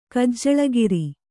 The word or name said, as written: ಕಜ್ಜಳಗಿರಿ